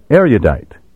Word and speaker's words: erudite: Today, most people pronounce these words with a long U, erudite, erudition.